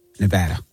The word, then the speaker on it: Nevada: People always say that's amazing, but how does that help Nevada? Did I say it right? – Mm-hmm. Yeah, you said it right.